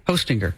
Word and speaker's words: Hostinger: H-O, like Santa, ho, and then stinger. – Hostinger. – Like a bee has a stinger. – But it's Hostinger. – Yeah, that's just how you spell it. – But it's like hosting, then you put an ER at the end.